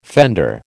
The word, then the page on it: fender: American English